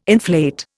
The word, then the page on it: inflate: Indian